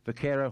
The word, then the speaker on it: vaquero: Vaquero horsemanship.